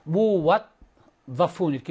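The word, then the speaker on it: 무왓다푼 (مُوَظَّف): 더 무왓다프 근데 여기에 무왓다프인데 샷다가 있어. 그러면 무왓다프이에요. 무왓다프 그럼 발음 어떻게 하냐? 무왓으로 해요. 무왓 왓으로 발음하고 더푼 이렇게 하겠죠. 무왓다푼. 발음되는 것은 여기에 샷다가 있어서 두 개가 있단 말이에요. 그러니까 이게 지금 두 개가 있는 거예요. 이렇게. 그래서 무왓다푼 이렇게 두개 바뀌어서 무왓다푼.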